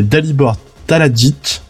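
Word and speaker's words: Talajić: Ça a été écrit par Cullen Bunn et l'artiste c'est Dalibor Talit. Oh, j'arrive pas à le prononcer, je suis désolé s'il nous écoute. L'artiste c'est Dalibor Taladjit.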